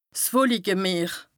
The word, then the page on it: 's Wolikemeer: Bas-Rhin; Strasbourg